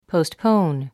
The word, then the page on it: postpone: poustpóun; ポゥストポーン